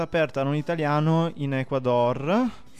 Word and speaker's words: Ecuador: Hai detto giusto, Ecuador. – Ah, io ho detto Ecuador. – No, è Ecuador giusto.